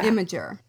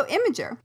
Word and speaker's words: Imgur: Imgur? – Imgur? Imgur. – Yeah. Imgur. Okay. So Imgur.